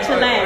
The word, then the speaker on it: Chile: Yeah, Chile. – Or did you say, is Chile. It pronounced Chile? – Chile. – I think so. – Yeah. – I think in America we say Chile, but they say Chile.